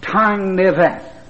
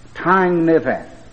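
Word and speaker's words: tangnefedd: But he pronounced it "Tang Neveth." "Tang Neveth."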